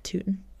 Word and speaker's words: Tuten: T-U-T-E-N. – T-U-T-E-N. Tootin. – Officer Tootin. – Officer Tootin. – That's what how I was reading it in my head, but I don't know. – I mean. – Tootin, Tutton.